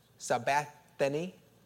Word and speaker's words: sabachthani: How do you say it? Sabachthani. That's close enough? Yeah.